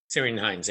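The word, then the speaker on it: Ciarán Hinds: But like I said, I knew that Sirian Hines, I think is how you pronounce his name. – Sirian Hines, yeah.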